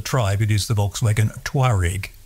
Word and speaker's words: Touareg: Everyone at Volkswagen, and indeed virtually everyone everywhere, pronounces Touareg. So they lose one whole syllable in there. So it's the Volkswagen Touareg.